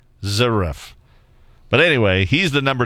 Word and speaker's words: Zverev: The men's semifinals happening this morning already, and Alexander Zverev, he beat the British guy, who's been like the Cinderella story there.